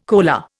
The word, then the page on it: coaler: Indian